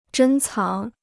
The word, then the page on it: 珍藏: zhēn cáng